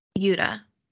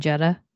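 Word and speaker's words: Jutta: Also, I was definitely pronouncing it Jedha. – 100%. – Mm-hmm. Oh, I was 100%. Completely shocked when I heard how it was pronounced.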